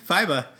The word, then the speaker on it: FIBA: Yeah, FIBA.